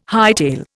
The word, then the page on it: hightail: Indian